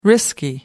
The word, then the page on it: risky: American English